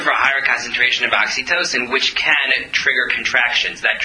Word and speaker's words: oxytocin: oxytocin. And the result of that, am I pronouncing that right? I hope I got the chemical name right. No, I'm not. Oxytocin, thank you.